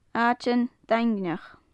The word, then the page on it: aitean daingneach: gaelic